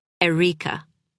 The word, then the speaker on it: Erica: He put the emphasis on the second syllable, Erica. It made her name sound more exotic.